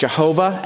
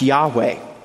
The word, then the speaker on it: Yahweh: We used to think it was pronounced Jehovah, and that's a good expression, but our best approximation is that the divine name is pronounced Yahweh.